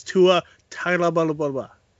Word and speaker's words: Tua Tagovailoa: By the way, everybody, it's Tua Tagovailoa.